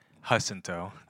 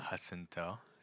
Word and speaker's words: Jacinto: That's Jacinto. – Oh, for real? Have I been saying your name wrong this whole time? Hello, Christian Jacinto. – Jacinto. Yeah.